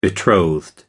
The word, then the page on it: betrothed: American English